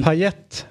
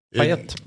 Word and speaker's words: Payet: Payet faktiskt, märkligt nog uttalas. – Payet, ja, för att normalt sett så tar man bort, men just på honom så ska Tete vara kvar. – Jaha. Payet.